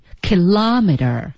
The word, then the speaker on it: kilometer: 是啊，但是这个单词呢，大家要注意它的读音的重音呢在 kilometer。